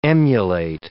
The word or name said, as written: emulate